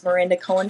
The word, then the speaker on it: Miranda Cohen: Um, so I would say Instagram, and my name is Miranda Cohen Fit.